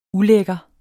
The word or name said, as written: ulækker